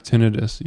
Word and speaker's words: tinnitus: Was it tinnitus? Am I wrong? – It's pronounced tinnitus, yes.